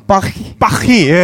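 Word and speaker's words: Paris: 파리도 파리 아니에요. 파히파히 예. 어, 좀 그러니까 달라요. 알 발음이 특히 파리 쪽은 알 발음을 흑 하고 발음하는 게 있어가지고 그래서 그거를 제가 택시 타기 전에 한 15분 동안 연습을 했어요.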